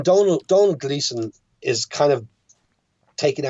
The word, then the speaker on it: Domhnall: How do you pronounce his son's name? – Donald. – Because in America, we always call him Domhnall, but I know that's incorrect.